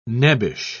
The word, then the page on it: nebbish: British English